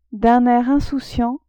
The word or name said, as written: d'un air insouciant